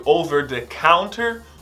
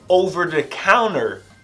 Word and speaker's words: over-the-counter: Você vai ouvir tanto over-the-counter ou over-the-counter. Esse T não é pronunciado às vezes pelos americanos.